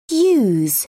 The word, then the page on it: yous: British English